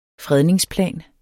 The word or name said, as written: fredningsplan